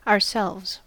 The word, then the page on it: ourselves: American English